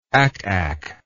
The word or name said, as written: ack ack